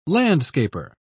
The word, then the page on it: landscaper: British English